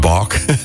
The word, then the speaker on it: Baak: Moet je dat zo uitspreken hier? Ja, het is gewoon Baak. Maar Baak.